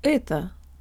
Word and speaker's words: это: Это. Это. Это. Tell your brain the O represents a schwa vowel and make sure to de-emphasize the last syllable.